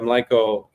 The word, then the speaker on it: mlíko: So the first one, do beers poured in the mlíko, is that how you say it? – Yeah. – The glass? – Mlíko. – Mlíko.